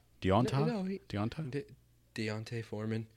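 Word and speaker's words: D'Onta Foreman: Next guy we're going to talk about is Dante Foreman, the backup, as people are predicting. Did I say it wrong? Deontay Foreman.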